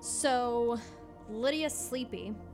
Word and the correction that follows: Sleppy: is that how you pronounce her name? – Sleppy. – Sleppy? – Sleppy. – We'll go with Sleppy.